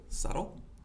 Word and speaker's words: subtle: What is the word? Subtle. Yeah. How do you spell that? S U S U B T L E.